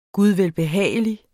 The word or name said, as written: gudvelbehagelig